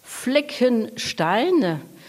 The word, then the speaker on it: Fleckenstein: Fleckenstein.